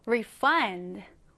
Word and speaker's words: refund: refund가 동사로 쓰일 때, 즉 환불하다라는 의미로 쓰일 때는 강세가 뒤에 들어갑니다. refund, refund 이렇게요. 그런데 명사로 쓰일 때는요. 앞에 강세가 들어가게 됩니다. 그러면 refund, refund가 되겠죠? 자, 여러분, 환불하다라는 의미일 때는 refund, 환불일 때는 refund 이렇게 발음하시면 됩니다.